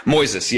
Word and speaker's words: Moises: Is it Moises? – Moises, yeah. M-O-I-S-E-S.